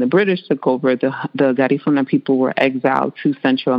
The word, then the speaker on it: Garifuna: What is Garifuna? I know I said that wrong. – You were close. Very, very close. So it's Garifuna.